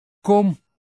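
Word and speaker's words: Qom: Phát âm là kom, có thể được phiên âm thành kwam, wam, kwam.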